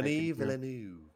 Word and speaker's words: Denis Villeneuve: Timothy Chalamet, for the people that don't know, superstar coming out of the Denis Villeneuve.